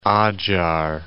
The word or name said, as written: Adzhar